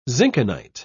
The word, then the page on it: zinkenite: British English